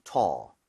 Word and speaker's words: tall: A-L-L은 올이 아니라 얼에 가깝다는 느낌으로 제가 두번 발음해 드릴게요. Tall, tall.